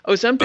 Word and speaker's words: Ozempic: Ozempic, Ozempic.